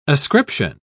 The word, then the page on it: ascription: British English